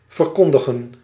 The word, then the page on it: proclamer: [pʁɔ.kla.me]; France